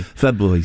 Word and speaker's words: February: February. February. So I'm saying it properly.